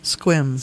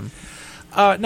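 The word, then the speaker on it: Sequim: Squim. – Squim, Washington. – Yes, yeah, Squim.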